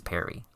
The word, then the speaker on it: Perry: Well, his name does rhyme with scary. It's Perry.